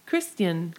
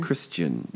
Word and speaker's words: Kristian: And, yeah, it's spelled very weird. K-R-I-S-T-I-A-N. – Christian. – Christian, yeah. She's played Hope Brady on Days of Our Lives from 1983 to now.